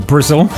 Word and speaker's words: Bristol: Oh, a bit of a plug while we're talking about Bristol, or Brizzle, as it should be pronounced.